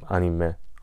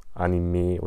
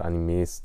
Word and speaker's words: Anime: Also im Original wird es eben Anime ausgesprochen. Und im Deutschen sagt man halt Anime oder Animes teilweise, aber es wird Anime auf Japanisch ausgesprochen.